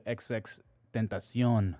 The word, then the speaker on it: XXXTentacion: We got some RIP to XXXTentacion. – Is that how you say it? – Yes. Okay. Tentacion.